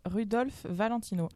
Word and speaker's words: Rudolph Valentino: Alors je commence par Rudolph Valentino.